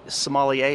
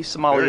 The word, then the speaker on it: sommelier: Sommelier, am I saying that right? – Yes. – A T. Sommelier. – Very good.